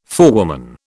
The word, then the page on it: forewoman: British English